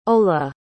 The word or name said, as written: ola